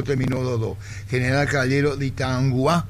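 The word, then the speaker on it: Itauguá: Así se pronuncia, ¿verdad? ¿Dije bien, Itanguá, niño? – Sí, Itanguá.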